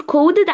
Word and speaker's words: coded: coded، coded بنقول كودد عشان